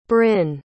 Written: brynne

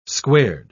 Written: squared

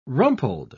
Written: rumpled

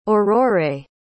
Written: aurorae

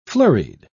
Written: flurried

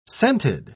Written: scented

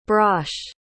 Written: brosh

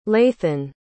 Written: lathan

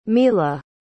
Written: milla

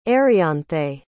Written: arianthe

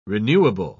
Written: renewable

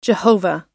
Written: Jehovah

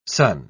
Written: sun

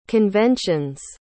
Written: conventions

